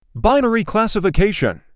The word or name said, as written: binary classification